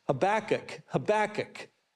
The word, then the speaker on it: Habakkuk: Habakkuk is where we're at today, and I'm pronouncing that like my Bible professor in college pronounced that, Habakkuk 1:1.